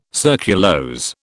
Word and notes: surculose: British English

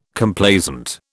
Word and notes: Complaisant: British English

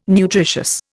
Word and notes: nutritious: Indian

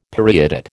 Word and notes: periodate: American English

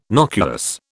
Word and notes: nocuous: British English

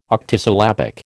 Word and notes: octosyllabic: American English

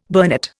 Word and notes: burnet: Indian

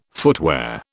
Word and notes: footwear: British English

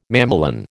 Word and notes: mamelon: American English

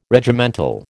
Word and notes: Regimental: American English

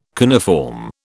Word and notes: coniform: British English

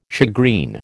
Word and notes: shagreen: American English

